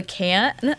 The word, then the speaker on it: khaen: Which apparently it's called a can, but it's spelled K-H-A-E-N, or like an alternate spelling is K-H-E-N-E.